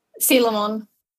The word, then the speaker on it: Coelamon: For some reason, I always go with Coelamon because the spelling pops up in my head. – Oh, yeah, me too. Since Digimon World, I've just been pronouncing as Coelamon, but it is Sealamon.